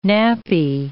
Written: nappy

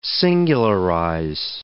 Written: singularize

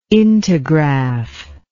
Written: integraph

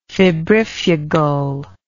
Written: febrifugal

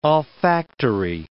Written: olfactory

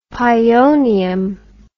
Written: pionium

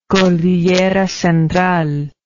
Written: cordillera central

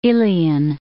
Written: Ilian

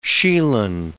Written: shieling

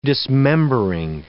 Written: dismembering

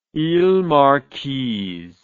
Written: iles marquises

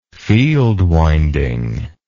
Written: field winding

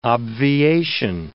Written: obviation

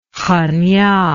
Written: Khania

